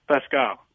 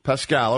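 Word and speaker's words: Paschall: I've had two Warriors beat reporters as guests on the program, and they have pronounced Eric Pascal or Pascal, the last name, two different ways. Mm. I want you to break the tie for me. Tell me exactly how to say that last name so I never butcher it again.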